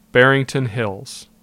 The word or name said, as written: Barrington Hills